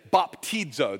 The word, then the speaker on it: baptizo: On the count of three, we're going to say baptizo. That's how you say it.